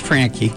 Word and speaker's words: Franke: Bob's last name is F-R-A-N-K-E, and pronounced Frankie, Bob Frankie.